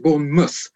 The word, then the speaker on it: Bournemouth: Yeah, because it's Bournemouth, but you pronounce it Bournemouth.